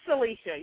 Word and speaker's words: Syleecia: It's Syleecia. Yeah, you got it right.